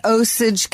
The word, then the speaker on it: Osage: It's August Osage County.